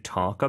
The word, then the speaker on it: talk: Do you want to talk about it? – 빈칸에는 Talk, talk. – 여기서도 L 발음 하나요, 안 하나요? – 안 합니다. – 그렇죠. talk라고 하지 않습니다.